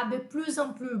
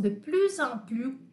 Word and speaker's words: de plus en plus: Então, eu não pronuncio esse segundo S aqui.